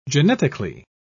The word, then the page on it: genetically: British English